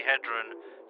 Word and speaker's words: Hedren: Well, he got Tippi Hendry I pronounce to be Hendron, Hedron, Tippi Hedren, to be Hendry.